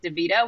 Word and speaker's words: DeVito: Yeah, I mean, if you want to follow me on socials, my handle on everything is at Lauren Doritos, on account of Doritos rhymes with DeVito, which is my actual name.